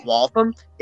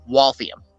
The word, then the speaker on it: Waltham: So it's not, in case you're curious, it's not Waltham, it's Waltham. – You're adding an I in there that doesn't exist.